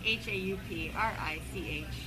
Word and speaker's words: Hauprich: Uh, M A R I E T J E H A U P R I C H.